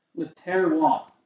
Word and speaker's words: Le Terroir: I don't even remember how to pronounce it. – Le Terroir. – The Terroir, that's right.